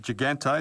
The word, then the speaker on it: Gigante: Another name I want to mention to you, Matt, and I know you rate this young athlete so highly, Sarah Gigante.